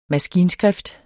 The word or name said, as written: maskinskrift